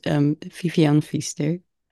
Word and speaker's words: Vivian Viester: In Dutch, it's Vivian Viester.